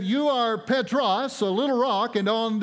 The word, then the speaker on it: Petros: The word Peter is the translation of a Greek word that is pronounced Petros. You hear the word Peter in it. And the word Petros means little rock, little pebble, little stone.